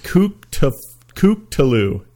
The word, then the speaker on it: Cthulhu: He says it's pronounced Kooktaloo.